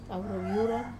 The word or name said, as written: kavaru viurar